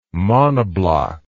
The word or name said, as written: monobloc